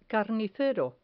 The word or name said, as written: carnicero